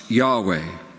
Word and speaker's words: Yahweh: Shorter forms of this are YHWH, pronounced Yahweh or Jehovah.